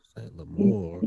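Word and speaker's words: St. Lemor: He goes by Saint Lamour. – How you spell that? Saint? – S-T. Yeah, S-T dot L-E-M-O-R. So it's a play off the Moore people. – Oh, okay. – Yeah. – Saint Lamour.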